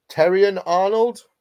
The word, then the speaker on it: Terrion Arnold: Terrion Arnold, is that how you say it? – Yeah, well done.